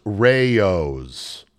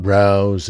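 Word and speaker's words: Rao's: But Rouse, that restaurant, they're popping up at the Ryder Cup, the famous expensive exclusive restaurant in downtown New York, is actually pronounced Rayo's. Oh. I know because I've heard it on the Food Channel and I was similarly chastised when I dared make the same mispronunciation at work. P.S. Yeah, my work is a really fun place to work. It's not Rouse, it's Rayo's.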